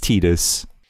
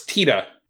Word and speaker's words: Tidus: But then I guess that is the accepted pronunciation of Tidus is Tidus. – Except in Japan where it's Tita. – Tita, that's right. – But it's still spelled T-I-D-U-S.